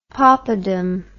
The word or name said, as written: popadam